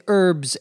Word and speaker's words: herbs: That's why the title of this episode is Using Herbs and Spices in Cooking. Herbs, H-E-R-B-S, notice that in American English the H is silent, you don't pronounce it, are leaves or other green parts of a plant that are used for seasoning food, to give food a certain flavor.